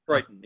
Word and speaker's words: Crichton: Well, you were asking about his name. It is Crichton, yes. – Okay. Crichton wrote a book called Prey that also dealt with nanotechnology, nanobots.